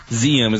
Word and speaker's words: Zeum: it's spelled with a Z, Zeum, is going to have a Star Wars, the Clone Wars event on, not this weekend, but in 2 weekends.